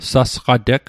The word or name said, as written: sasqateg